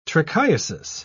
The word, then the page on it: trichiasis: British English